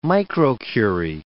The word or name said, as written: microcurie